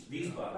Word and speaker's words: Wiesbaden: Their W's are not the way that we pronounce W's. Theirs is V's. Wiesbaden.